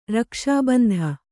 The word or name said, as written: ರಕ್ಷಾಬಂಧ